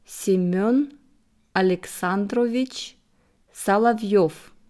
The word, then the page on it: Semen Aleksandrovich Solovov: slow